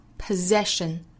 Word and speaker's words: possession: 所以呢虽然都是字母 S, 但对应的发音呢不一定发成 S, 这个词要注意发 possession, possession, 它的动词形式是 possess, possess, 中间的两个 S 也是读浊音的。